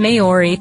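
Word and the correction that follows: Māori: throughout the podcast. So we have to apologize for that and correct it. It is pronounced Maori, or at least that's an acceptable pronunciation, I think, for Yanks like us.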